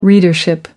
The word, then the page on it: readership: American English